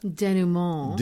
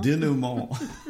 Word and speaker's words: denouement: Denouement. – Denouement.